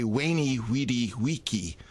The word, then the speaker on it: veni, vidi, vici: In classical, you'd actually say veni, and it was a classical statement from Julius Caesar. You would say veni, vidi, vici. So the V, the pronunciation of the V, whether as a W or a V, the pronunciation of a C, whether a hard C, like a K, or in Vici, or like Gloria in Excelsis.